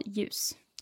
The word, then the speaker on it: ljus: So they're both called ljus. – Ljus. And it's got, is it like a silent L? Do you not say the L? It's spelled L-J-U-S. – Yeah, exactly.